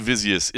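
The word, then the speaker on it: Jasudavicius: By the way, Jasmine Divizius is the proper pronunciation of her last name, so they were saying it right on there.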